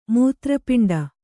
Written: ಮೂತ್ರಪಿಂಡ

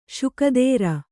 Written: ಷೋಕೀದಾರ

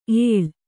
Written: ಏಳ್

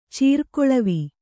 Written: ಚೀರ್ಗೊಳವೆ

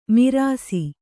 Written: ಮಿರಾಸಿ

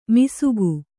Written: ಮಿಸುಗು

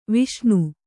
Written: ವಿಷ್ಣು

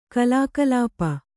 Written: ಕಲಾಕಲಾಪ

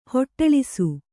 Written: ಹೊಟ್ಟಳಿಸು

